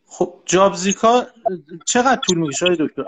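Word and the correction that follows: Job Seeker: مهندس تلفظ درستش جاب زیکا نیست، جاب سیکره. سیکر یعنی جستجو کننده.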